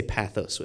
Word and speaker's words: pathos: You guys say pathos with me.